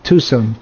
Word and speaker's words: Tucson: How do you pronounce it? Tucson? – Tucson. – Tucson. Sorry, excuse my ignorance.